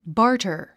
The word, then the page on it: barter: bάːrtər; バーター